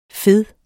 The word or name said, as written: fed